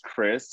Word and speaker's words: Kris: So my name is Chris, spelled with a K. So K-R-I-S.